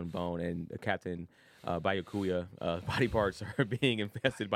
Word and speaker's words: Byakuya: Byakuya Kuya, I'm saying that wrong again. – Byakuya and Renji collapse, and they are in critical condition.